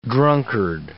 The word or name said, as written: drunkard